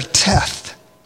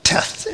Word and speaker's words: Teth: And this one begins with the letter Teth, T-E-T-H. And actually, it's just the T-E-T is what's pronounced in it.